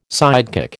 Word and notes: sidekick: American English

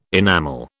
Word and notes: enamel: British English

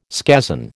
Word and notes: scazon: American English

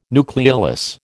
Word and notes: nucleolus: American English

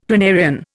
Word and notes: planarian: Indian

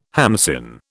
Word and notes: hamsin: British English

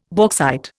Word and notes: bauxite: Indian

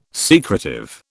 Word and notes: secretive: British English

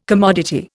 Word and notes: commodity: Indian

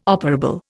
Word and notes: operable: Indian

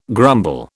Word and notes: grumble: British English

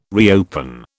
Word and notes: reopen: British English

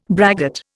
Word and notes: braggart: Indian

